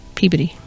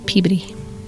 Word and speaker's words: Peabody: They say Peabody. So now I say that and act like I'm really cool because I know stuff. – I would have said Peabody. I would have said it wrong. – It's Peabody. There you go.